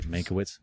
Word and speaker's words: Mankiewicz: Did I say it right? Mankiewicz? – Yeah.